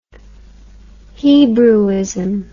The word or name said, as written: hebrewism